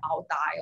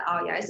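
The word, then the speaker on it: áo dài: I hope I'm pronouncing this right. I think there was once I was like, okay, I don't really know if it's like i dai or something like that. And then I, I went to um, find how to pronounce it on YouTube. And it's the Aoyai.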